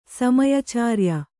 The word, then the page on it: ಸಮಯಚಾರ್ಯ: kannada